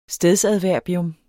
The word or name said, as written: stedsadverbium